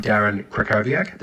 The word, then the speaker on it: Krakowiak: Hi, I'm Darren Krakowiak, the W is pronounced as a V.